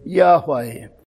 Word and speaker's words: Yahweh: which comes from the four Hebrew letters J-H-V-H with vowels interspersed, Jehovah, but probably more accurately Y-H-W-H with two vowels inserted to make the pronounceable word Yahweh.